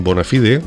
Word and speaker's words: Bonafide: Y con Bonafide, que se pronuncia así porque es latín, pues te diremos que no nos marchamos para siempre, que en un ratito, unos días, pues volvemos contigo de nuevo.